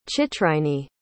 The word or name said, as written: chitrinee